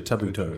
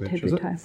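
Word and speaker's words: Tabuteau: But for me, the most interesting deputy leadership issue this week, of course, has been, can you say his name? Tabutel? – Fletcher Tabutel. – Fletcher Tabutel.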